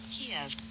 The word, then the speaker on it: Kyiv: Kyiv.